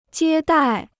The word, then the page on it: 接待: jiē dài